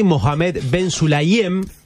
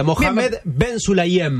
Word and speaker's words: Mohamed Ben Sulayem: Mohamed Ben Sulayem, su nombre. – Aquí árabe. – Bien lo pronunciaste, ¿eh? – ¿Sí? Sí, muy bien, vamos de vuelta. Mohamed Ben Sulayem.